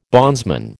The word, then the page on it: bondsman: American English